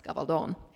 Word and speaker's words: Gabaldon: My actual name is a Spanish name, and were we speaking Spanish, it would be pronounced Gabaldon, hence the long O in the last syllable.